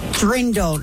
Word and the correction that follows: Dirndl: No, Dirndl. – Dirndl is the correct answer.